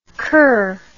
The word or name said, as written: coeur